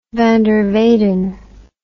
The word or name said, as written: van der weyden